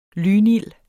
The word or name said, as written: lynild